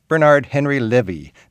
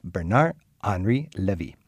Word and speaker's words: Bernard-Henri Lévy: Bernard-Henri Lévy. No, Bernard-Henri Lévy.